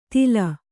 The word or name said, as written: ತಿಲ